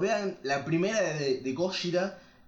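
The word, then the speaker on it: Gojira (ゴジラ): Y, no se dice Godzilla, se dice Gojira.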